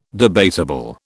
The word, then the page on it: debatable: British English